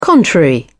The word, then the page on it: contrary: British English